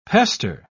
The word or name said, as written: pester